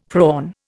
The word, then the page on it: prawn: Indian